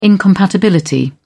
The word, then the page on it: incompatibility: British English